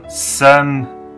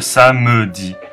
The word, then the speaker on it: samedi: 注意两点，第一点就是 samedi 是发 samedi 而不是 samedi，那个 a 是不发音的。